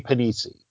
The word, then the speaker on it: Panisi: He plays Eddie Panisi, but if you look at his last name, it's spelled like Penisy.